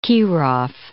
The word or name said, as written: Kirov